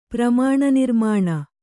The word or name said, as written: ಪ್ರಮಾಣನಿರ್ಮಾಣ